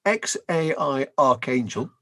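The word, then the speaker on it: X Æ A-12: I think it's worth mentioning because Elon Musk and Grimes already had a child, their son called XAE A12, which is pronounced XAI Archangel, as if you need to be told that.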